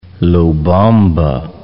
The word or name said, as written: Lobamba